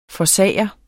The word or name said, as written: forsager